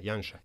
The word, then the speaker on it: Janša: do we pronounce it Janša or Janša? – Janša.